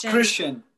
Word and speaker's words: Christian: It's Christian. Yeah, it's Christian. Okay. Like the religion Christian.